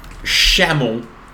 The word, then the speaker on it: Shamal: Shamel. – Shamel? – Shamel. – Shamel. – Spell it. – S-H-A-M-A-L.